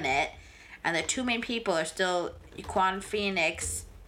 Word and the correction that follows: Joaquin: Joaquin Phoenix? You say Iquan? He's fantastic. – I always say it wrong.